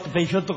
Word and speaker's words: Peixoto: No quería pronunciar la X. – Peixoto como chiquilín.